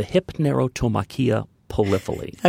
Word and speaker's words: Hypnerotomachia Poliphili: Hypnerotomachia polupali polypoly. That's very close. That's very good. It's a mouthful. The Hypnerotomachia Poliphili.